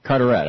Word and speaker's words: Carteret: Glenn in Carteret, New Jersey, Carteret. – Am I pronouncing? Carteret, okay. Glenn, you're on the air.